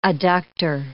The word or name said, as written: adductor